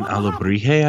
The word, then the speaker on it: alebrije: Yeah, alebrije. – Alawaha. – Um, an alebrije, I believe, is the pronunciation.